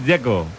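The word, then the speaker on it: Džeko: El que me parece difícil es el de Checo, Dzeko, porque DZ, ¿cómo se pronuncia? – Dzeko. Bruno dice que es Dzeko. – Como tirando a J, Dzeko o algo así.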